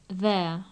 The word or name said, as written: their